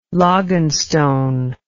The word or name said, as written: logganstone